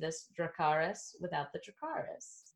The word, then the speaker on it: Dracarys: And I literally called this one Dracarys without the Dracarys. Is that how they pronounce it? – Dracarys. Yeah.